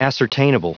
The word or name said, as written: ascertainable